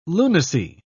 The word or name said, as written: lunacy